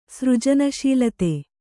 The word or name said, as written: ಸೃಜನಶೀಲತೆ